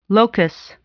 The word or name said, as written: locus